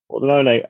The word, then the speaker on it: Pordenone: and I am taking a home win for Brescia against, the pronunciation is going to be horrible, Pordenone? – Yeah, that's right. – Pordenone.